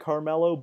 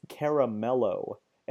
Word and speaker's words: Caramello: It's not I mean maybe you could pronounce it Carmelo, but it's caramello. And I have always pronounced it Carmelo.